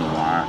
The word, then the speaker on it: noir: It was right after World War II, I believe, when they started making intense film noir.